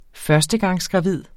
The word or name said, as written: førstegangsgravid